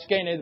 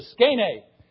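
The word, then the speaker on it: skene (σκηνή): And just to make sure I pronounce it right, skene, the best I could anyway, I pronounced it this morning while I was sitting in my chair, and I said, skene. And my wife says, thanks for calling me skinny. So maybe that'll help you remember it.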